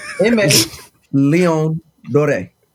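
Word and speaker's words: Aimé Leon Dore: Amy Leon Dore. – Dore? What, you French? – No, the photographer on Trap Karaoke is from Canada, so he told me how to say it.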